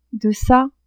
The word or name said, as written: deca